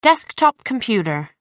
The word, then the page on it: desktop computer: American English